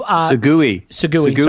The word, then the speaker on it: segue: So we will segue to segue. – Sorry, my bad. – I've seen it spelt. – I got the pronunciation problem.